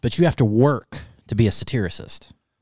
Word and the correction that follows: satirist: A satirist.